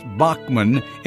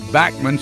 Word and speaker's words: Bachman: In the United States, it's pronounced Bachman. In his native Canada, he says Backman. So to me, his name should be Tal Backman.